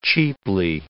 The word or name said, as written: cheaply